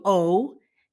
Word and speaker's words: owe: Se escribe O-W-E, pero se dice O.